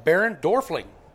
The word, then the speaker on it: Barend Dorfling: And then we got a special guest with us, Baron Dorfling.